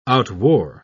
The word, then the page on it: outwore: British English